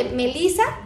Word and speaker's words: Melissa: Me van a encontrar con mi nombre, Melisa Tejeda.